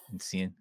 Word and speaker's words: Essien: So this week we have Michael Essien. Did I pronounce that right, Amobi? – Yep. – Essien, okay.